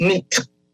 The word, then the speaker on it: night: N-I-G-H-T. – Uh-huh. – That used to be pronounced neat. That G-H had a sound.